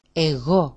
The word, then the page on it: e6o: e6o-